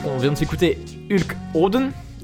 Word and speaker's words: Hodn: Hulk je pense que ça se dit Oden, ça s'écrit H-O-D-N.